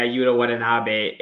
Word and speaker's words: Watanabe: Yeah, Yuta Watanabe.